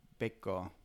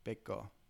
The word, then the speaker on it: Bechgard: Yeah, in Danish, like Bekko. – Oh. – Is how you would say it. Say it again. – Bekko.